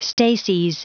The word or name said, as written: stases